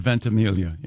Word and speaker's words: Ventimiglia: Is it Ventimiglia? – Does he go with the soft G or does he do the Mike Birbiglia thing? – I have heard the soft G. – I've heard Ventimiglia, yes.